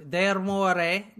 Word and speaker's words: D'Amore: And one Benjamin De Amore, that's the last time I'm going to say it like that. – De Amore. – De Amore. Everyone say it, please. De Amore. That's how you say it. I looked it up.